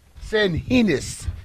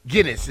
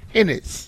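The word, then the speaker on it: San Ginés: San Henis. It's not Guinness. It's Henis. – So it's spelled S-A-N. – And it's a chaka G-I-N-E-S.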